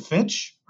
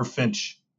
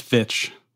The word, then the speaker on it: Fitch: Is it Fitch or Finch? – I think it's Fitch. – Fitch.